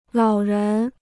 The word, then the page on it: 老人: lǎo rén